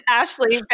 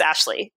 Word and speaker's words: Ashleigh: This is Dutch and O-U-T spells out. So it's Vanhouten. And yes, my first name is spelled a little bit different, but if you just take 2 seconds to look at how it's spelled, you will see very clearly that it's Ashley.